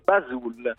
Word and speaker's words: Basul: E allora, cofondatore e amministratore legato di Buzzulle. Come si legge Buzzulle? Buzzulle? – No, si legge Basul. – Basul? – Esatto.